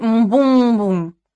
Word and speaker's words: Мбум-бум-бум: Родом он из джунглей Мбум-бум-бум». Ударение на 3-м слоге от конца.